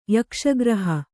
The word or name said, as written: ಯಕ್ಷಗ್ರಹ